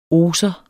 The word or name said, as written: oser